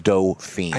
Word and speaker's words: Dauphine: But it's a Dauphine. It's pronounced Dauphine. – I know, but I like Dauphine. – Dauphine.